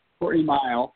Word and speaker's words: Courtney Mile: Courtney Mile. It's spelled like it should be pronounced Miguel, but it's actually Mile.